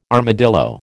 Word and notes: armadillo: American English

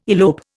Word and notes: elope: Indian

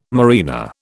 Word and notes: marina: British English

